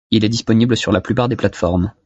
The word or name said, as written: formes